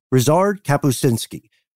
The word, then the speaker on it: Ryszard Kapuściński: Ryszard Kapuściński.